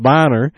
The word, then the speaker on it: Boehner: I always call him Boehner, I don't know, how, I guess it's pronounced Bonner, has provided it yet again.